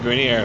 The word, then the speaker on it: Granirer: That voice, folks, is our guest, Mr. David Granirer, who's a comedian here in Vancouver.